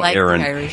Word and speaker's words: Aaron: I'm sorry I mispronounced your name, Erin. – It's actually Aaron, so that's all right. – It is? – Yes. – Oh. Well, Gary, yeah, somebody, we all, Erin. We don't care. It's spelled Aaron, and I'm pronouncing it now Aaron.